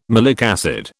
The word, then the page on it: malic acid: British English